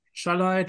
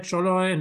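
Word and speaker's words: Szalai: Also die haben diesen Attila Schalai, Scholoi, Namenaussprache ist nicht so hm? meins mhm. geholt. – Scholoi, also, Scholoi glaube ich.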